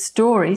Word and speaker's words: Storie: And my surname is Story, spelled Storie.